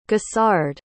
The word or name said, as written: gossard